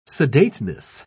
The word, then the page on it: sedateness: British English